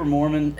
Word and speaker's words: Mohrmann: It's just Cooper Mohrmann everywhere.